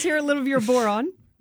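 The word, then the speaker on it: bodhrán: Well, I brought the Irish drum, which is called the boron. Conveniently rhymes with moron. – Oh, but it's spelled B-O-D-H-R-A-N. – Because I call it called the Bodran.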